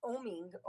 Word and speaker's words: OMing: O-M. It's oming. Oming. O-M.